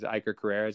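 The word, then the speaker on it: Iker Karrera: Is Iker Carrera, is that how you say his name? – Yes.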